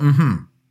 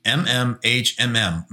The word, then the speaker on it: Mm-hmm: And we discovered it recently this summer, and it's still out in beta, I believe, but it's called Mm-hmm. And yeah, I said that right. It's M-M-H-M-M, Mm-hmm, is the name of the app.